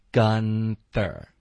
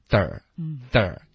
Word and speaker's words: Gunther: 最后呢，我们就有 from Gunther。那 from Gunther 的这个发音，没有什么变化。注意，gun 结尾的 n 呢，大家咬舌尖，gun 发这个 n 的音，这样你发 ther 的时候呢，你的舌头已经碰到上齿了。 Gunther，舌头就弹到嘴巴里面，卷舌。 Gunther。